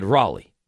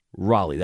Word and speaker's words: Raleigh: I said, Raleigh. And then the conversation continues. Santiago's like, phonetically, like I said, you remember the pitcher, Raleigh Fingers? Raleigh, that's it.